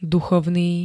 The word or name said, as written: duchovný